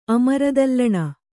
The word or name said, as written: ಅಮರದಲ್ಲಣ